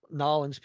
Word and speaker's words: New Orleans: We dub y'all official Nollins people, man. Y'all can just pronounce it Nollins.